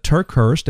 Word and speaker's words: TerKeurst: Many of you ladies out there will know Lisa Turkhurst, and let me spell that for you, L-Y-S-A. Last name is T-E-R-K-E-U-R-S-T.